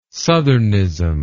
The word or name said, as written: southernism